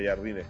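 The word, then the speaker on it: Jardim: Este sí se escribe como Jardín, pero con M, Jardín. No, no como Jardine, que cada quien le dice como quiere ahora. Se pronuncia Jardine, y lo dijeron en la conferencia de prensa de América.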